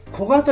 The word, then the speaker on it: 古河: 古いに山水の川と書いて古河と読みますが、この古河の街中を走っています。